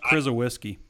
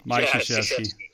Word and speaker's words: Krzyzewski: It's pronounced Krizowiski. – I haven't even had coffee yet. That's why I was like, this is the podcast. – Mike Krzyzewski.